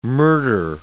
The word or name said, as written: murder